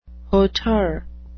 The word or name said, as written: hauteur